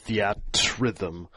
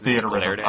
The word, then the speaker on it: Theatrhythm: I called it the at rhythm, and then someone got pissed off at me. They're like, no, it's theater rhythm.